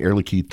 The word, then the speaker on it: Air Liquide: Air Liquide signs 11 new long-term contracts for on-site gas supply. – So first thing, Air Liquide, thank you for saying their name right. Right? They're a French company.